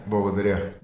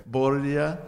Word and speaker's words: Благодаря: Благодаря. – Благодаря.